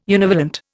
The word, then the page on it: univalent: Indian